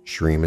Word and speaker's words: Shreem: Shreem is actually pronounced Shreem, where you drag out the middle part to run for the duration of the breath.